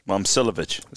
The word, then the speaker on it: Momcilovic: Of course, Momcilovic. – See, I was wrong. This is why you don't rely on me with the pronunciations.